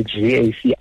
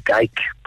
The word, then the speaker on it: GAC: I've seen people say GAC. I say Geic, but I think GAC might be the more appropriate approach here.